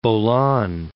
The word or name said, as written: Bolan Pass